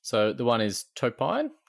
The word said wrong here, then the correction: Tupini: Obviously, it's Tupini, mate. – Tupini.